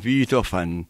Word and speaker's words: Beethoven: Bueno, los alemanes dicen Beethoven. La doble E la hacen I y la B corta se pronuncia como F en alemán.